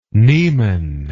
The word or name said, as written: Niemen